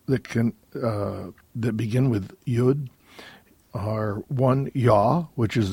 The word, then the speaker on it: Yud: I pronounce it Yud in that Israel, Israelites or Israelis, Israelis pronounce this letter Yud in Israel. But I've heard it also from American Jews on both coasts, from New York and also from Los Angeles, pronounce this Yod.